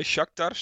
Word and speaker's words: Shakhtar: Ja, dat is, dan moet dus op de laatste dag moet Atalanta winnen bij Shakhtar, zeg ik dat goed?